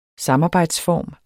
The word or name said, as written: samarbejdsform